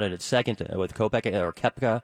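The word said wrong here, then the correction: Koepka: The name is just one of those things I messed up. Kopka is actually how it's pronounced.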